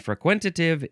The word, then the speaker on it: frequentative: frequentative. Yep, that's how you say it. Frequentative.